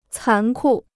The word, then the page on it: 残酷: cán kù